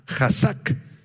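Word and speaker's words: hazak (חזק): Es un verbo hebreo, es el verbo hazak, con Z y acento en la segunda A, hazak.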